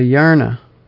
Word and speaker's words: Yarna: Yeah, I was going to pronounce it with a different vowel at the end. It's a Yarna.